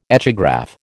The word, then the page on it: echograph: American English